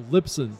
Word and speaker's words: Libsyn: We are down at the podcast show in London, and joining me now from Lisbon. Am I saying it right? Lisbon. – A Lipson.